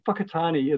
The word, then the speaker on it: Whakatane: Whakatane is the correct pronunciation for the hometown where I was born, not Whakatane. Yeah, Whakatane is not a rude word, believe it or not. WH is in Māori is pronounced with a F.